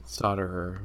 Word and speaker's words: solderer: Yeah, me too, because I am the worst solderer in the world, I'm pretty sure. – Solderer, very nice. – Is that not how it's said? – Solderer. – What? The L is silent.